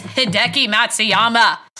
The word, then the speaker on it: Hideki Matsuyama: I'm like, it's Hideki Matsuyama!